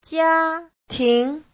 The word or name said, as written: jia1ting2